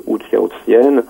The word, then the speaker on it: Hou Hsiao-hsien: Et il y a un cinéma pour le cinéma asiatique, les films de Hou Hsiao-hsien.